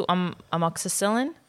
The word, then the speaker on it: amoxicillin: She's allergic to amoxicillin. – You said it right. Yes. Oh, God. You always say it wrong.